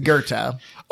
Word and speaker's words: Goethe: It's pronounced Goethe. – That can't be right, can it? – It's right. It is. If you learned any German, then you would know that it's Goethe.